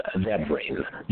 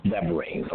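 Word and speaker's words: Devarim (דברים): The Hebrew word for this book is pronounced Devarim. Devarim.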